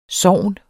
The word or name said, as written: sogn